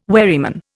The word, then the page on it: wherryman: Indian